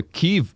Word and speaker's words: Kyiv: Honestly, it was like they were going to Kyiv. It was just insane. – I know that's how you pronounce it. – It's how you pronounce it now.